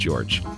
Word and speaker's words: Gyorgy: And we'll remember that it's pronounced George.